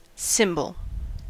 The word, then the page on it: cymbal: American English